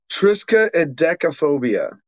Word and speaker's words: Triskaidekaphobia: Trixadecaphobia. – No, you already screwed it up. – Triskaidekaphobia. – Yeah, I think I got it right. At least phonetically complete. Triskaidekaphobia, also known as the extreme superstitious and fearful views of the number 13.